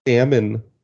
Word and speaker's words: salmon: Salmon. They put an L in there because.